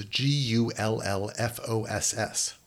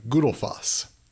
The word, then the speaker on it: Gullfoss: The spelling of this waterfall is G U L L F O S S, which looks like Gullfoss. But that double L in Icelandic is pronounced like tull, as in the word battle. And the U isn't an uh, it's in more of an oo sound, Gudelfoss. Foss, that suffix in Gudelfoss means waterfall.